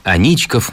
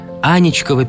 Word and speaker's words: Аничков: Аничков мост. Трехпролетный каменный мост через Фонтанку. Многие туристы неправильно произносят его название. Аничкова переправа. С ударением на первый слог. Это ошибка. Никакой Ани, и уж тем более какой-то там мифологической Анечки, в истории моста никогда не было.